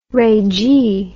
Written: regie

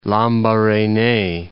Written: Lambarene

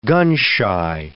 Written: gun-shy

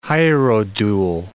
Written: hierodule

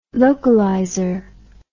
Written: localizer

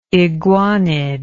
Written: iguanid

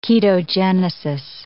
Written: ketogenesis

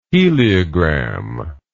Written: heliogram

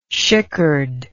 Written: shickered

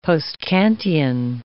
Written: post-Kantian

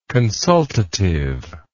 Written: consultatively

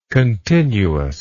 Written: continuousness

